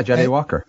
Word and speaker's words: Jahdae Walker: Then there was the final pass of the regular time at, from Jahdae Walker. Is that how you say his name? – Yeah, Jahdae Walker.